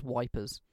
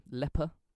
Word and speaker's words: Ypres: which is spelt Y-P-R-E-S. The British soldiers refer to it as wipers. – Yeah. – It's a fucking bastard of a word to say. – I think it's just pronounced Ypres. – Yeah, Ypres. It depends what language you say it in. – Oh, yeah, yeah. Because if you're like... – In Dutch or Belgian, I think it's Leper.